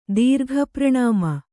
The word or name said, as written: ದೀರ್ಘಪ್ರಣಾಮ